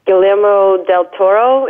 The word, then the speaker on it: Guillermo del Toro: Well, because overall, I know the director was Guillermo del Toro, is that how you pronounce it? – Yeah.